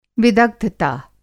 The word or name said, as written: विदग्धता